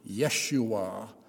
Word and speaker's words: Yeshua: The baby was to be named Jesus, or more correctly in Hebrew, Yeshua, or as we might pronounce it in English, Joshua.